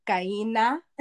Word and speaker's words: Kaina: I have to say it like my mom says it. Kaina.